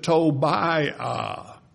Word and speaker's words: Tobiah: We pronounce it Tobiah, but really that last I-A-H belongs together.